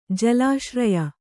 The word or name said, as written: ಜಲಾಶ್ರಯ